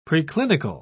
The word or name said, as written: preclinical